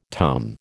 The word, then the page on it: tum: American English